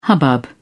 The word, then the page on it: hubbub: British English